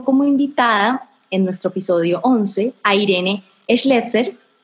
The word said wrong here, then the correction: Schlechter: ¿Schlechter? ¿Cómo se pronuncia bien tu apellido? Se pronuncia Schlechter. Schlechter fundadora de The Bonding, una startup que anunció eh, su exit la semana pasada.